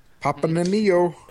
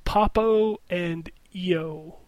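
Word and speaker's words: Papo & Yo: None of us have said it right yet. – Papa and Neo. – It's Papo and EO. – Papo and EO. – I believe that's what it's called.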